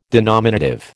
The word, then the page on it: denominative: American English